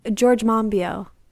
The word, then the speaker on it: Monbiot: And part of that, I think, is because you have people like George Monbiot or Monbiot, I don't know how to say his last name. – George Monbiot.